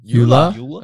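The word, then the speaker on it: Joola: Yula? – Okay. See, right. There you go. – Yula's, Yula? That's how in table tennis, everyone calls it Yula.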